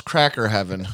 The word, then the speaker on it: Crackington Haven: Crackington Haven is excellent. – Yeah. – It's England. It's pronounced cracker heaven.